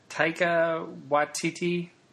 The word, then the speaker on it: Taika Waititi: Is it Taika Waititi? – Yes.